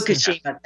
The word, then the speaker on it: Fukushima: Fukushima, thank you. I was doing one syllable twice and leaving the other one out.